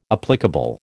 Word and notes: applicable: American English

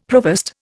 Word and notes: provost: Indian

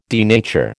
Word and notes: denature: American English

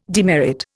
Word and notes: demerit: Indian

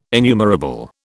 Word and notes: innumerable: British English

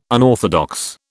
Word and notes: unorthodox: British English

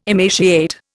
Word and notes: emaciate: Indian

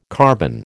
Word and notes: carbon: American English

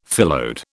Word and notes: phyllode: British English

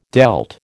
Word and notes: dealt: American English

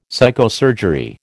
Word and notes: psychosurgery: American English

